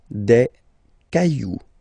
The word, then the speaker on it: cailloux: Then, un caillou, plural, des cailloux. Okay, remember, you don't pronounce it, the final X.